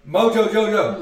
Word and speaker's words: Mojo Jojo: And so I walk in this tarp, and that Mojo Jojo, Moho, Yo-Yo. – Okay, you know how to say it. – Mojo-Yo. – Mojo Jojo! – Mojo Jojo. – Yeah, there you go.